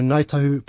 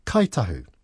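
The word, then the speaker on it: Ngāi Tahu: So sometimes you'll hear Ngaitahu pronounced as Kaitahu. That's also very, very common as well.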